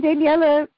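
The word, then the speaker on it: Daniele: Daniel Ganser, what was his speech talk about? – Daniela. I learned he pronounces it Daniela.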